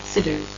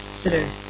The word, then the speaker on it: Sidr: Sidr. Sidr. S-I-D-R.